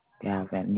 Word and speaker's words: Galvan: Galvan. – Galvan. V as in voyage or? – V as in Victor. Galvan. – Galvan, yes, ma'am.